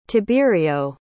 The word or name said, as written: tiberio